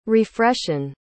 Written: refreshen

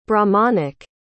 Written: brahmanic

